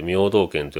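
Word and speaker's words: 名東県: ところが 1873 年には明道県、これはあの名前の名に東の県と書いて明道県と読むんですが、これは後の徳島県ですね。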